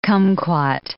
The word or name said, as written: kumquat